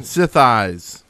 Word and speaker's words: Sithis: Sith eyes. – Yes. – I was like, swatter. – Rhymes with thighs or your eyes.